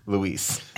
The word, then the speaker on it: Louise: Yeah, I think looking at Louise, it looks like Louise. – You might have to start writing them out phonetically. L-O-O. – I do it with June. I have E-E-S-E.